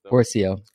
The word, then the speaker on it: Pourciau: Pourciau.